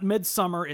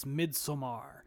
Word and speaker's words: Midsommar: I've learned that is actually how you're supposed to pronounce that movie. It's not Midsommar, it's Midsommar. And yeah,